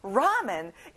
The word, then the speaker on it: ramen: 참고로 라면은 영어 발음으로 ramen이에요. 알겠죠? 그냥 물만 끓이면 돼서 아주 easy, 쉬워요. 여러분, 같이 따라해봐요. 만들다, make 이거 잊지 말고 한번 해볼까요? Ramen is easy to make.